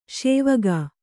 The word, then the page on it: ಶಿವಕ: Kannada